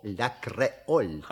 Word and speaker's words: Ля Креоль: Себастьян Ля Креоль. Правильно? – Не совсем. В слове «Ля Креоль» ударение должно быть на букве «О». «Ля Креоль».